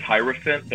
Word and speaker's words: Hierophant: Speaking of material, you have a couple great releases out as Young Hero Fan. Am I pronouncing it right? How do you pronounce that word? I never say it. – I say it as Hierophant, but no one Hierophant.